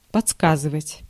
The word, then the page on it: подсказывать: /pɐˈt͡skazɨvətʲ/; podskazyvat